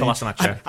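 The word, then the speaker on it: Kolašinac: Kolasinac.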